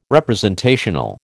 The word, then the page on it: representational: American English